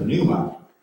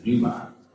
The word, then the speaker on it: pneuma: Someone who meant pneuma. I like to pronounce the P. Maybe you just heard it as pneuma.